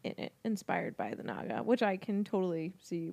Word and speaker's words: Naga: And so you can go on the wiki and it's pronounced Naga. I was right.